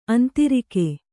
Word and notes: ಆಂಧ್ರಕ: āndhraka

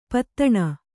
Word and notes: ಪೂದೊಣೆ: pūdoṇe

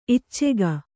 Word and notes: ಈಚುಕೈ: īcukai

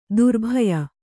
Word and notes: ದ್ರಬ್ಯ: drabya

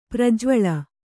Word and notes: ಪ್ರಜ್ವಳ: prajvaḷa

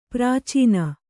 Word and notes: ಪ್ರಾಚೀನೆ: prācīne

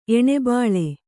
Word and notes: ಏಣುಬಾಳೆ: Kannada

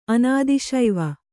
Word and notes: ಅನಾದಿಶೈವ: Kannada

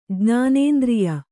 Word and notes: ಜ್ಞಾನೇಂದ್ರಿಯ: jñānēndriya

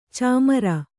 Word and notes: ಚಮ್ಮಾರ: cammāra

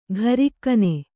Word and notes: ಘರಿಕ್ಕನೆ: gharikkane

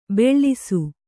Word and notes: ಬೆಳ್ಳಿಸು: beḷḷisu

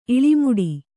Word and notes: ಇಳಿಮೇಡು: iḷimēḍu

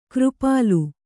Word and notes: ಕಿರಿಪಿಲ್ಲೆ: Kannada

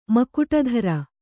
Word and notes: ಮಕುಟಧರ: Kannada

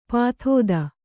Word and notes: ಪಾಥೋದಿ: pāthōdi

